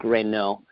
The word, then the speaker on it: Reynaud: our last name is called so many things, but I just thought I'd give that pronunciation. It's French. It's Luc Reynaud.